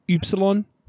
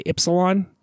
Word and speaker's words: Upsilon: Like Greek, you would pronounce it Upsilon, but Epsilon is the E, Upsilon. And Upsilon is the, like, Y. – Right, it's like Y and E. – U shape. And so it's... Here it's spelled with a Y, I say Ypsilon, even though in my classical Greek training, Upsilon. I would say Upsilon.